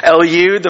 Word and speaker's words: Eliud: That's actually pronounced correctly, even though you're laughing. Eliud,